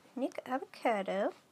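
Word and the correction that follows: Nikocado Avocado: Nicado Avocado. Ooh, I was saying it wrong.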